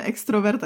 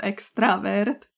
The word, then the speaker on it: extravert: Ale tady ten vzkaz od, od Hodzini pobavil, jednak nás upozornil na chybu, že vyslovujeme extrovert, a je to extravert. Jako, ex, extravert? jako extravert nám napsal, že je eh. extravert a že to říkáme špatně, tak bych vlastně chtěla veřejně opravit.